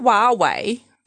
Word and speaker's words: Huawei: So I thought Huawei... That's how you say it.